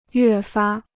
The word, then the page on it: 越发: yuè fā